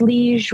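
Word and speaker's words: Liège: For dessert, is this Liege? Is that how you would pronounce it? I have not seen that before. – My Liege. – Yes, Liege waffle.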